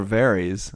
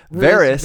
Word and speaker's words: Varys: I thought, you know, those close ups of the dragon, we didn't mention it last week, sadly, but the shot where Varys, see, no more Varys. I got the pronunciation right. – Varys.